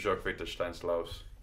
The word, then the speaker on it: Jörg Viktor Steins-Lauß: My name is Jörg Viktor Steins-Lauß.